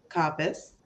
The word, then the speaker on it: Coppes: Today I have Vanessa Kappas, the founder of Bella Media, editor of Bella Magazine, and the co-founder of ETT Women.